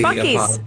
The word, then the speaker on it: Buc-ee's: Bussie, how do you pronounce B-U-C? – Buc-ee's.